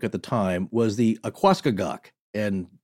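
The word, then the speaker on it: Aquascogoc: In this case, it's spelled A-Q-U-A, so like aqua, and then C-O-G-O-C. So to me, Akwaskagak.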